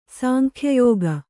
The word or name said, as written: ಸಾಂಖ್ಯಯೋಗ